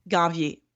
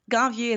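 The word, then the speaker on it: Ganvié: Ganvier. – Okay. – Ganvier is spelled G-A-N like Nancy, V-I-E like the Beyonce E with the accent at the end.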